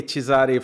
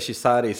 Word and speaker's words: Chizari: Uttalar jag ditt namn rätt nu, efternamnet? – Ja, det är Chizari, fast här blir det lättare att säga Chizari. Så det funkar vilket som.